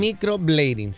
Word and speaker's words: microblading: el, microblading. Microblading, sí. Bueno, no sé si lo dije bien, pero por ahí va. ¿Cómo se dice? la producer. – Este dice microblading.